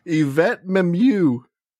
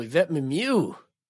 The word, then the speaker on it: Yvette Mimieux: Yvette Mimieux. – Oh, Yvette Mimieux.